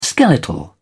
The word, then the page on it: skeletal: British English